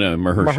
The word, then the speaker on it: Mahershala: Mahershala Ali, if I'm pronouncing that right. – Mahershala. – Can you say that again, please? – Mahershala. – No, Mahershala.